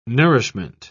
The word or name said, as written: nourishment